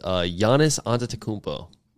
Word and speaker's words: Giannis Antetokounmpo: My Win of the Week, I'll go ahead and start this off, is Giannis Antetokounmpo. – I'm surprised you said that right.